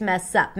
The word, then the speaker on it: mess up: Now let's talk about the phrase mess up. Mess up is a phrasal verb, and notice how she pronounces it. Mess up, mess up. So the stress falls on the word up, but here we see the connected speech.